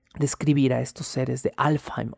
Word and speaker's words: Alfheim: Alfheim, Alfheim Elfheim, también pronúnciese, es la casa de los elfos claros.